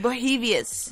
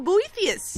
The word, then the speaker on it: Boethius: Boethius. – Sure. – There's no B. There's no B in that, isn't there? – No, it's Boethius.